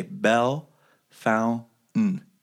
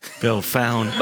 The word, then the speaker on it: Bellefontaine: But I'm sorry, I have to correct your pronunciation of Belle Fountain. It's a very common occurrence that newscasters and normal people alike will mispronounce our Belle Fountain, is it? – Belle Fountain, and if you're truly native, you take out everything but the very essentials. You say Belle Fountain.